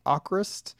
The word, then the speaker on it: Avkrvst: So you recently did a reaction video for the band called Akrvst, but spelled with V's, AVKRVST, Norwegian prog metal band.